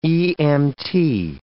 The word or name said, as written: EMT